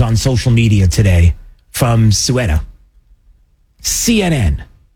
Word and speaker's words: Ceuta: Okay, I got to say it right. I've been saying Quetta, I've been saying Ceuta. Ceuta, I apologize.